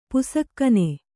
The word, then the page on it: ಪುಸಕ್ಕನೆ: pusakkane